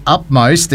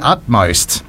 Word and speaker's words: utmost: And the number one thing that we all pronounce wrong is we say upmost instead of the utmost.